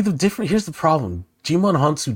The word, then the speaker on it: Djimon Hounsou: And Djimon Hounsou, is that how you Jim- pronounce Djimon Hounsou.